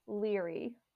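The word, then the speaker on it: Laoghaire: And there's one name, you pronounce it Leary, but you read it and it says Lago Hair.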